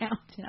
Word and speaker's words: Downton: No, it's not downtown. It's Downton.